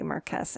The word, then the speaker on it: Marquess: In French it's Marquis, and that's how I always pronounce it, but I think it's technically Marquess in English, but he's going to be joining the House of Lords, and there's possibly people out to murder him.